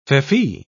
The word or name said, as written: feoffee